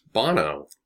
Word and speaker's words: Bono: Is it Bonner or Bono? – Bono. – Okay, so I was saying it right.